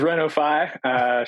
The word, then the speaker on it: Reno-Fi: It is Reno-Fi, short for renovation finance.